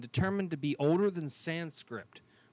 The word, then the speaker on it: Sanskrit: It's Sanskrit, by the way. – Sanskrit. – Sanskrit. – Sanskrit. – It's Sanskrit. – I don't care what kind of script it is. – It's Sanskrit. – Spell it. – I think it's S-A-N-S-K-R-I-T. It's Sanskrit. – Yeah. – That's what I said. – You said Sanskrit. Like it's written in sand.